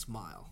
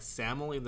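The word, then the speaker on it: SMIL: I didn't realize it was pronounced smile. – I don't know how it's pronounced, but I call it smile. I pronounced it like Samily. That's something completely different.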